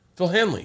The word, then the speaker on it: Phil Hanley: Am I saying it right, wrong? – I don't know. – We were gonna start a, I think it's Hanley. What is it? Phil Hanley. Yeah, I was right.